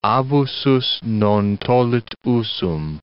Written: abusus non tollit usum

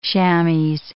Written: chamoix

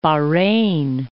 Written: Bahrein

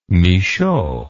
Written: Michaux

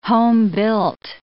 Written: homebuilt

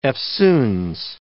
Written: eftsoons

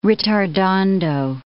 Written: ritardando

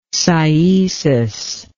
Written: cyetic

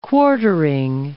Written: quartering

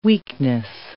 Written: weakness

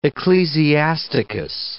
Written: Ecclesiasticus

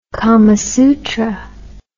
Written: kamasutra

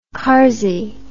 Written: carzey

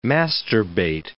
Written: masturbate

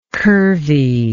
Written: curviness

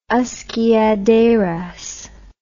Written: usque ad aras

